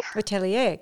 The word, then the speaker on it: Atelier: Am I saying that right? Atelier? – Atelier.